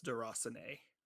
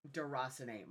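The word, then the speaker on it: Déraciné: It is actually pronounced Deracine. – Deracine, my bad.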